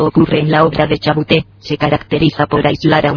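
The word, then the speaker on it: Chabouté: Madre, me ha apuntado por aquí: Purgatorio de Chabuté. No sé si mi pronunciación es correcta o no. – Sí, muy bien.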